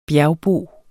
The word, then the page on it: bjergbo: -ˌboˀ